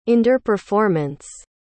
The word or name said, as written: underperformance